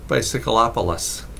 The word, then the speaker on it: Bicyclopolis: His YouTube channel is called Bicyclopolis. It's spelled like bicycle, except instead of ending with an E, bicycle with an E, it ends with opolis, as in metropolitan area. Opolis. So Bicyclopolis. – Yeah, O-P-O-L-I-S.